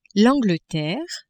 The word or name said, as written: Angleterre